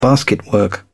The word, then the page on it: basketwork: British English